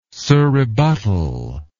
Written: surrebuttal

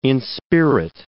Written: inspirit

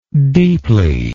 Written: deeply

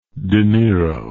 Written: De Niro

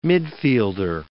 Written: midfielder

